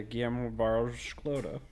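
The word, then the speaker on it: Guillermo Barros Schelotto: Yeah, Guillermo Barros-Galotto. – Yep, he's exactly great pronunciation.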